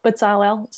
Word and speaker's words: Bezalel: Bezalel? Is that how? Bezalel? Sorry, my partner's there correcting my Hebrew pronunciation.